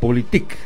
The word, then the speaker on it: Politik: Eh, para los que tengan duda, la palabra eh, a la hora de buscarla se escribe P-O-L-I-T-I-K. Politic.